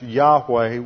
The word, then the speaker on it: Yahweh: And whenever you see the Lord spelled in small caps, like this, that is always a translation of the Hebrew tetragrammaton, YHWH, which is usually pronounced Yahweh. When the Jews read it, they never pronounce the name of God. They always read it Adonai.